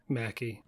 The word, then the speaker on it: Mackie: I was excited to see Anthony McKay back, or Anthony Mackie, whichever one. – Mackie.